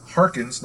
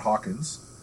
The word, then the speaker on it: Harkins: It's Bolio and Harkins, not Hawkins.